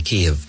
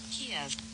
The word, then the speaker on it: Kyiv: This is how you pronounce it. – Kyiv.